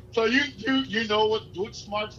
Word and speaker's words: Deutschmarks: Deutschmarks. I was like, what's Dutchmarks? You were like, no, you're saying it wrong, bro.